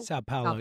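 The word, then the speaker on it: São Paulo: Today's question, do you like the Eagles opening their 2024 regular season on a Friday night in, how do you say this, Sao Paulo, Brazil? – Sao Paulo, yeah.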